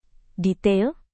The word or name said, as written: detail